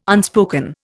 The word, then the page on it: unspoken: Indian